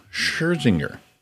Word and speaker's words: Scherzinger: Singer and host, one of the hosts of The Masked Singer, Nicole Scherzinger. Is that how you say it? – Yeah. – Nicole Scherzinger.